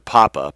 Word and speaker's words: Pappe: Once while visiting Danzig, I had occasion to visit Mr. Papa, P-A-P-P-E, to give the German pronunciation, then Polish High Commissioner, in the large building which accommodated Warsaw's ambitious representation.